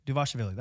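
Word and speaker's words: Dvalishvili: Divashvili. I think that's right. Yes. – Maybe. – Duvashvili. That's right. That's correct.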